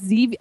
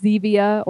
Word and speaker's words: Zevia: It's called Zevia. I think it's Zevia or Zevia, depending on how you pronounce the stevia or stevia. But it's Z-E-V-I-A, and they have all different kind of flavors: root beer, like Sprites, ginger ales, Cokes, orange sodas.